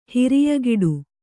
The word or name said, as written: ಹಿರಿಯಗಿಡು